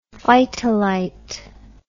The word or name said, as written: phytolite